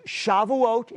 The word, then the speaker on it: Shavuot: it's pronounced Shavuot in Hebrew, and in English, we call it Pentecost.